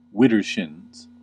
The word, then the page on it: widdershins: American English